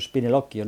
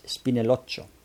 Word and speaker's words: Spinelloccio: Por cierto, creo que al inicio, en la presentación de los personajes, lo dije mal. Me parece que dije el maestro Spinelloccio. No, es Spinelloccio.